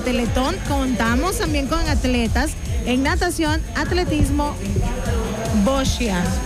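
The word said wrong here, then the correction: boccia: Yeah, ¿cómo se pronuncia esto, hijo? – Bocha.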